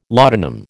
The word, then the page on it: laudanum: American English